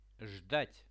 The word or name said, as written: zhdat